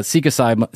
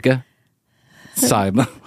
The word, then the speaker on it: psilocybin: One of my friends, a couple of months ago, about a year ago, decided he was going to do uh, seek asylum, seek asylum, seek Psilocybin.